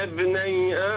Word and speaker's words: ابني: هذه الهمزة الثانية همزة وصل، لا تنطق. واتل عليهم نبأ بني آدم واتل عليهم نبأ ابني آدم بالحق إذ قربا قربانا فتقبل من أحدهما.